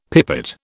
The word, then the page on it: pipit: British English